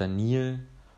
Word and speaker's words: Daniel: Mein Name ist Daniel und wird ausgesprochen wie Daniel, der durch Ägypten fließt.